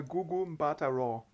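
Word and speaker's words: Gugu Mbatha-Raw: Also, Warte, ich sage Gugu Mbatha Raw. – Raw, ach so, ich sage, glaube ich, mal Roy, habe ich, glaube ich, gesagt, aber ne.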